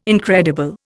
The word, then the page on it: incredible: Indian